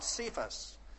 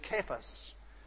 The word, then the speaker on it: Cephas: And those of you who have your Greek New Testament in front of you will know that the word here is Cephas, not Cephas. It is a hard K and it's a long A in the Greek language. It is Cephas.